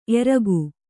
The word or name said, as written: ಏರಿಕ್ಕು